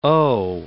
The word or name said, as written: oh